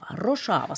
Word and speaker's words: Rojava: Hur ska jag uttala det? – Rojava, så säger vi.